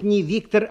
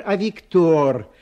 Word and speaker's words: Виктор: Во-первых, не Виктор, а Виктор. Во французском языке ударение на последнем слоге.